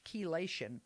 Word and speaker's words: chelation: And you know that word chelation, which is not spelled with a K, but a CH, chelation,